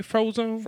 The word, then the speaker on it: Frozone: Am I saying that right, Frozone? – From The Incredibles.